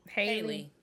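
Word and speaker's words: Halle: But I'm just saying that for me, Holly Bailey or Haley, I don't know how it's pronounced, but her. – Haley.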